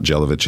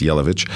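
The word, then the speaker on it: Jelavić: It's a silent J, so it's not Jelavic, it's Jelavic.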